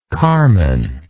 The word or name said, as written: carmen